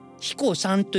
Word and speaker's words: 英彦山: 英雄の英に山彦海彦の彦という字を書いて彦さんと読むんですけども彦さん